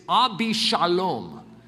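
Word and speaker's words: Avshalom: You know that that's actually not how you pronounce it in the Hebrew? I'm not exactly sure how we come up with our transalliterations, how we pronounce things when they don't even really sound similar. It's not that it doesn't sound similar, but it's Abishalom, which you'll recognize if you've gone through Ellerslie or spent time in this church.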